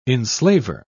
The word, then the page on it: enslaver: British English